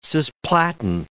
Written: cisplatin

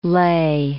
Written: Leh

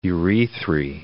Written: urethrae

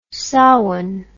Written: sawan

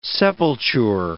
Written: sepulture

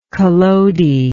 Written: Collodi

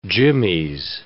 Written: jimmies

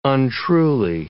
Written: untruly

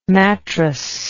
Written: mattrass

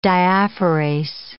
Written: diaphorase

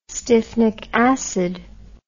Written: styphnic acid

